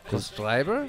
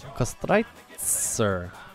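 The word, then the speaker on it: Köstritzer: Kostreiber? – Because we called it Kostreiber because we're morons. – What's it called? – Kostreitzer. TZ.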